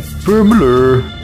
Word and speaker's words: Bromölla: Or Bromölla, you know, we never quite get it right. It might actually be pronounced Bromölla or something. I like the way you did it. Say that again. – Bromölla. – Perfect. Absolutely perfect.